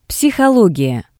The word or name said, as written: psihologiya